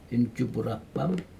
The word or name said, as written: injupurapam